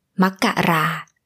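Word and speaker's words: Makkarakom (มกราคม): It is Makkarakom.And usually in the Thai language, we can drop the final syllable just to shorten it out. So, Makkarakom, the January, can become Makkara in a casual conversation.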